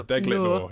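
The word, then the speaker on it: Deglet Nour: These dates are, now I don't even know how to pronounce it, Degle Noir. Is that it? Degle Noir? – It could be. – Degle Noir? I don't know. My French isn't great. I've got a D. – It's spelled Deglit. – Yeah, Deglit Noir.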